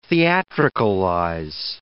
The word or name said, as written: theatricalize